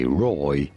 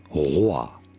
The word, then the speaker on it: Roi: No, we do not say Roy, but rather Roi. Roi is how we would say it in France, Roi. But it'd be fine in English to say it as Roi.